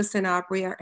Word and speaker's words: Sanabria: My name is Eva Sanabria, and I am the aunt of a special needs student.